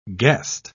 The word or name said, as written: guest